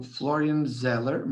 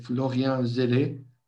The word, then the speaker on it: Florian Zeller: Acho que há aqui uma ausência de peso, na minha opinião, que é, e vamos ver se eu sei pronunciar o nome dele, porque estou a ver agora que ele é francês, que é o Florian Zeller, mas devia ser Florian Zeller, provavelmente, que é o realizador de Father, que eu acho que é uma realização.